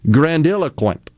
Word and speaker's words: grandiloquent: I'm not sure if I accented the proper syllables there in my pronunciation. Grandiloquent. – Grandiloquent. – I did it right.